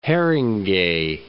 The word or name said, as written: Haringey